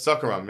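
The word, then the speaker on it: Seokguram: I know I mispronounced the names, but... – Seokgoram. – Seokgoram, yeah.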